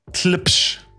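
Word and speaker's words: Tlepsh: Tlipsh, yes. – It's T-L-E-P-S-H for those that want to check it out on Bandcamp or YouTube and the rest.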